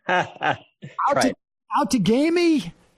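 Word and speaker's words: Outagamie: And Tom, you're going to have to help me with the pronunciation. out to gamey. – That's it. You got it.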